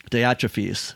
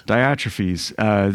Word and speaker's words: Diotrephes: Diotrephes. – Diotrephes. There. Diotrephes.